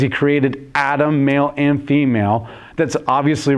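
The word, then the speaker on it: Adam (אדם): The word Adam in the Hebrew is Adam. It's one of the very few words that we didn't like change the pronunciation when it came into English.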